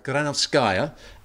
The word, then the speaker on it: Granovskaya: Now, bidders for Chelsea have been granted the opportunity to meet Marina Granovskaya. Is that how you say it? – Granovskaya.